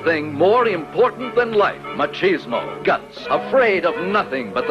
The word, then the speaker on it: Machismo: It's called Machoism. Machoismo. 40 Graves for 40 Guns. I think I'm saying that right. – Harry Novak presents the first motion picture bold enough to be called Machismo.